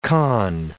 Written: conn

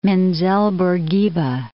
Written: Menzel Bourguiba

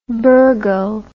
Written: burghul

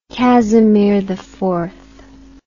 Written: Casimir IV

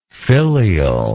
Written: filialness

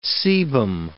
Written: sebum

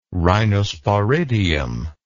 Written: rhinosporidium

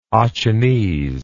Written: achenese

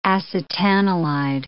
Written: acetanilid

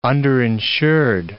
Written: underinsured

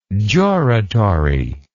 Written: juratory

